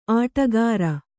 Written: ಅಡಿಗಾರ

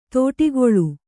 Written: ತೋಟಿಗೊಳು

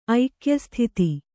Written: ಐಕ್ಯಸ್ಥಿತಿ

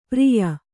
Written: ಪಾರಿಯ

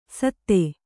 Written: ಸತ್ತೆ

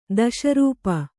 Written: ದೋಷಾರೋಪ

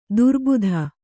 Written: ದುರ್ಬುಧ

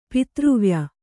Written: ಪಿತೃವ್ಯ

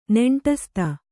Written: ನೆಂಟಸ್ತ

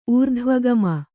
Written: ಊರ್ಧ್ವಗಾಮಿ

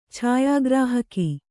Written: ಛಾಯಾಗ್ರಾಹಕ

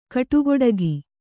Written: ಕಟುಗೊಡಗಿ

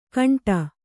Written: ಘಂಟೆ

